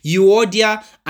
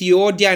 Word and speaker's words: Euodia: It says, I plead with you, Odia, and I plead with Syntyche. I can't remember how to pronounce that. I was listening to it the whole day on audio Bible, but I've forgotten the pronunciation. Euodia and Syntyche.